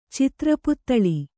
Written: ಚಿತ್ರಪುತ್ತಳಿ

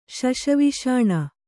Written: ಶಶವಿಷಾಣ

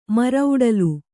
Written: ಮರಔಡಲು

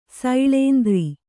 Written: ಸೈಳೇಂದ್ರಿ

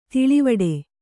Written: ದೂಳುವಡೆ

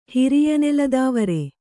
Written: ಹಿರಿಯನೆಲದಾವರೆ

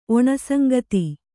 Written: ಒಣಸಂಗತಿ

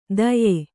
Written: ಧಾಯ್ಯೆ

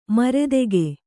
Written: ಮರೆದೆಗೆ

